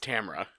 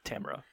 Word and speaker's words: Tamara: It's Tamara. – Tamara. – Because it rhymes with camera, and she spends her time talking into a camera.